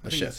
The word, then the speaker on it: La Cachette: Lachette? – Yeah, Lachette.